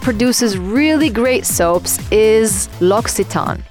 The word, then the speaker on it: L'Occitane: So it is pronounced L'Occitane.